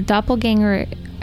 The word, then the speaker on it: doppelganger: Is a doppelganger, am I saying that right? – Yeah.